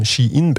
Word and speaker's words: Shein: Ja, und auch wir haben uns mit dem Online-Modehändler Shein befasst, so wird er nämlich laut unserer Recherche ausgesprochen.